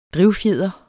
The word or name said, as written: drivfjeder